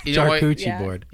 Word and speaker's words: charcuterie: I want a charcuterie board. – Yeah. Oh, we have it, did I say it right? – Charcuterie. – Yeah. Charcuterie.